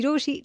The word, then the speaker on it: Rochie: मेरा नाम है मिर्ची रोशी, नाम की स्पेलिंग है ROCHIE, और मेरे साथ लाइन पर वेदांत है.